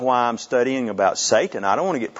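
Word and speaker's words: Satan (שטן): In the Hebrew, it's pronounced Satan. S-A-W-T-A-N. Satan. That's the way they would say it.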